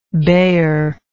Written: baeyer

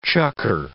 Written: chukar

